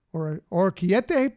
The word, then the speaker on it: orecchiette: Kids will find menu options just for them, including, I've never been able to say this pasta right. Orecchiette pasta. I know that's completely wrong. So it's funny, you know. – How do you spell it? – O R E C C H E I E T T E. – Oh, okay. Yeah. – Yeah. Yeah. Now you can understand why I have problems with that pasta. Never been able to say it right. I've used it many times, but always struggle with it.